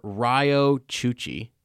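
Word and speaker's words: Riyo Chuchi: Now, enter Senator Ryo Chuchi. Did I say that right? – Yes, you did.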